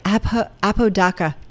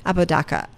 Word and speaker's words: Apodaca: Nathan Abitaka, I don't think I'm saying it right. Apodaca. Thank you. Apodaca.